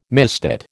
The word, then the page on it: misdate: American English